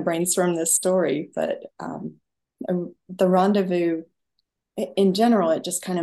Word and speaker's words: rendezvous: And it seems fitting that your new book is titled Rocky Mountain Rendezvous. Am I saying it properly? Rendezvous? – The way Americans pronounce it would be rendezvous. That S is silent.